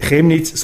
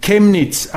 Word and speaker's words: Chemnitz: Und dann noch eine Korrektur, ich würde Chemnitz falsch aussprechen. Chemnitz, die Stadt in Sachsen, werde nicht Chemnitz, sondern Chemnitz ausgesprochen.